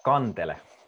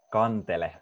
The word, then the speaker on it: kantele: Kantele. – Okay, how do you say it again for us, please? – Kantele. – Kantele.